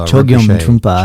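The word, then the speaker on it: Chögyam Trungpa: that you then did study with Chongyong Trungpa? – Chogyam Trungpa. – Sorry, I was mispronouncing it.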